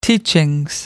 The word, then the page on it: teachings: British English